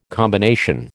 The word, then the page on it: combination: American English